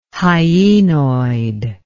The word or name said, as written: hyenoid